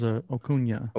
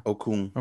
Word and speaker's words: Okung: Is it Okung? I thought it was Okunya. – Okung. – Okay, I'm wrong on that.